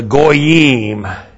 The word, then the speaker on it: goyim: Them is then defined as the goyim. G long O Y I M.